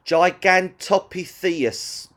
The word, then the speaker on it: Gigantopithecus: Gigantopithecus decorus.